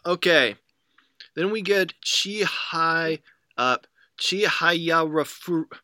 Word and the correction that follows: Chihayafuru: I never know if I'm saying this right. – Chihayafuru. Chihayafuru.